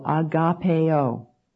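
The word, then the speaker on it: agapao: Now, the Greek word for the word love here, used in both of these great commandments, is the verb agapeo. Let me spell that for you. It's A-G-A-P-A-O, agapeo.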